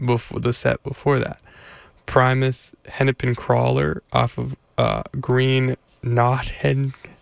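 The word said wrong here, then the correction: Naugahyde: It says on the CD how to pronounce it. Wait. Okay, hold up. Green Naga Hide. Oh, I was not close.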